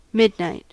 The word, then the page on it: midnight: American English